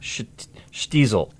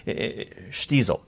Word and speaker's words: Shtisel: But it's spelled S-H-T-I-S-E-L, correct? – Yeah, that's right. – Shtiesel. – Shtiesel. – Don't say it wrong. It might sound like something else, but Shtiesel.